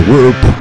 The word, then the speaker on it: Warp: זה וורפ, כאילו עיוות, W-A-R-P, אבל אי אפשר להגיד רופ בעברית, אז זה נקרא להם רופ, ותבינו למה אני מתכוון.